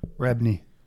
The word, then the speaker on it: Rebney: And Scott Coker, don't get me wrong, Scott Coker is a good president to have, but Neil Rentney, or how you pronounce that damn name. – Bajorn. – What's his last name? – Rebney. Rebney? – Tomato, tomato.